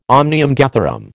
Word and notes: omnium gatherum: American English

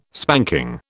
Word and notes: spanking: British English

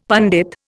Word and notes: pandit: Indian

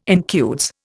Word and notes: incudes: Indian

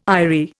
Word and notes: aerie: Indian

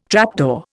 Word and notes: trapdoor: Indian

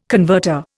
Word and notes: converter: Indian

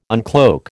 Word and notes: uncloak: American English

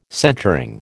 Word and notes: centring: American English

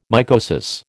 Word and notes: mycosis: American English

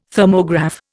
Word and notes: Thermograph: Indian

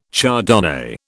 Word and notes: Chardonnay: British English

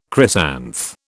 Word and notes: chrysanth: British English

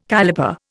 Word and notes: caliper: Indian

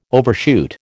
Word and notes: overshoot: American English

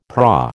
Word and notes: prau: American English

